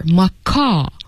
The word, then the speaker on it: macaw: But macaw, so you really should pay attention that you don't pronounce the W here, macaw.